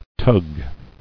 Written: Tugging

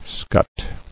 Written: Scut

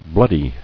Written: Bloodiness

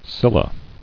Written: Scylla

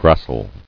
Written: Gracility

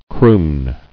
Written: Crooning

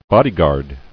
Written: Bodyguard

